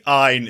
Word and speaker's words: Ayn: I think Ayn is how you're supposed to say it.